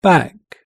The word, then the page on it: back: British English